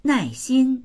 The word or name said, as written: nai4xin1